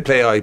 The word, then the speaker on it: Plai: They said Play Eye. P-L-A-I is how to spell it.